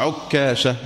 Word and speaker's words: عكاشة: قال الإمام النووي: الرهيط بضم الراء تصغير رهط وهم دون عشرة أنفس، والأفق الناحية والجانب، وعكاشة بضم العين وتشديد الكاف وبتخفيفها، والتشديد أفصح، عكاشة هكذا تنطق.